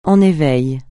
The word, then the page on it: en éveil: francais